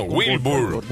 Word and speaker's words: Wilbur: Wilbur. – ¿No ha nacido todavía? – Yo tampoco. – ¿Recuerdas cómo era? Wilmar. – No, Wilbur. Wilbur.